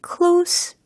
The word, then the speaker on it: close: walls 라고 했으니까 w-a-l-l-s 겠죠? 벽들이요. were closed on me. 이게 무슨 의미일까요? 특히 이 close 라고 발음이 되잖아요. 어, 철자는 똑같은데 이게 만약에 close 라고 들린다면 형용사적으로 활용이 되는 거고, 어, 닫다 라는 동사로 활용이 된다면 close 이와 같이 끝부분에 유성음으로 발음이 된다라는 차이가 있습니다.